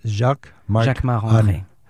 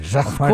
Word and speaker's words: Jacquemart-André: Jacquemart-André. You say it like a Frenchman. Jacquemart-André. – Of course, because I'm French. So Jacquemart-André.